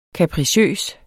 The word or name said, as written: kapriciøs